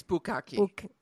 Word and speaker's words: bukkake: I don't even know if I'm pronouncing it correctly. Is it bukkake? – It's bukkake.